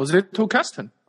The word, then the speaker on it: Zettelkasten: 叫 Zettelkasten 啊，这个呢是一个应该是个德文的发音，嗯，它是怎么说呢？